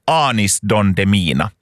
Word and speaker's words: Anis Don Demina: och jag frågade att hur uttalar man den här artistens namn, och jag tror att de sa Anis Don Demina.